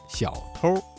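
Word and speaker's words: 小偷儿: 这句话当中啊，小偷一词的偷加了一个卷舌音日，发生了语流音变，形成了儿化音，读作小偷。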